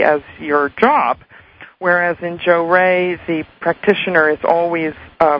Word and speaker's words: Johrei: And one of the things I'm involved in is called Jo-Rei. It's from Japan. It's spelled J-O-H-R-E-I.